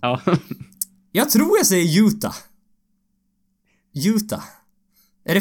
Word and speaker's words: Utah: De skrev ju ut det så med bokstäver, så man, så hur man ska uttala det. Då var det typ I-O-O. Ja, Utah. sen T, om något T-A-A typ.